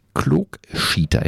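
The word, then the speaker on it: Klookschieter: er bezeichnet sich selbst als Klogschieter. Jetzt musst du mir sagen, ist das richtig ausgesprochen? – Du ja, bist ja der plattdeutsche Vogel. Sehr gut gesagt.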